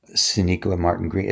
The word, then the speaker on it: Sonequa Martin-Green: Sonequa Martin-Green.